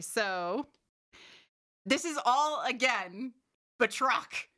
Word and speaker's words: Batroc: But Georges, I don't think I'm pronouncing that correct because it's a French name. – Batroc. George Batroc.